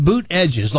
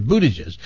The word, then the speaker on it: Buttigieg: Buttigieg. – It's Pete. Yeah, he says it's pronounced boot edges, like two words. Buttigieg. That's how he says you pronounce it.